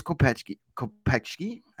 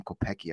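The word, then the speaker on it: Kopecky: And they also brought in his buddy, Thomas Kopechki. I always say it wrong. I always just want to call him Kopecki. I want to pronounce it like it's spelled.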